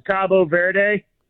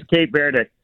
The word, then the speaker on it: Cape Verde: How do I say that country? – Either way, you said it right. That's the it's kind of like Turkey, Turkey. – Yeah. – They in Spanish, it's Cabo Verde. – Cabo Verde. – Cabo San Lucas or whatever in Mexico. Cabo Verde. But okay. – The English pronunciation is Cape Verde.